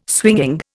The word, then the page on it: swinging: Indian